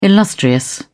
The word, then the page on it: illustrious: British English